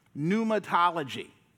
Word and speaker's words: pneumatology: Yep, pneumatology. And you may recognize the pneuma. Yeah, the word that starts with a P and not an N, right?